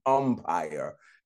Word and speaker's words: umpire: I said, I'm saying empire all week. I knew I was saying umpire. I was reading it, but I'm pronouncing it with an E.